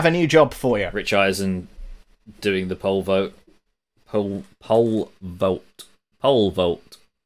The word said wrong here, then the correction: pole vault: Why can't I say vote? pole vault. Pole vault? You're saying it right.